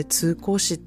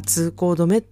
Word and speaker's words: 通行止め: 日本人で通行しって読む人誰もおらんでって言われて、あれは通行止めって読むねんでって言われて、もう本当に大恥をかいたんですね。